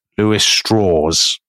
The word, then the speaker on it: Strauss: Well, it's spelt Louis Strauss, but he always pronounces it Louis Strauss. I will explain why. He's from West Virginia. He is also of German Jewish stock. But to downplay his Jewishness in a world that is still quite anti-Semitic, he pronounces it Strauss, because he kind of thinks it'll sound more Southern and less Jewish.